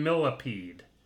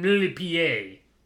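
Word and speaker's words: Millepied: Millipede. But it turns out... – Oh, no! – It turns out he's a French dancer, so it's pronounced Benjamin Millipied.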